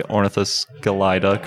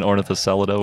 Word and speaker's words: Ornithoscelida: Getting me to say Ornithoscelida correctly rather than Ornithoscelida, which is what I've been saying. – We've been wondering. – Some people do leave it as a soft C, but it's, I think it's because it's from the Greek skelios, which is a hard, it's a K actually in Greek. So I think you hit the C hard.